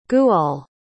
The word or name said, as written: gwawl